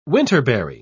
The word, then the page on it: winterberry: British English